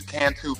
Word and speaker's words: Cantu: It is not an acronym, but there are five letters that I need you to write out to pronounce. C-A-N-T-U. T as in turtle. Cantu. C-A-N-T-U.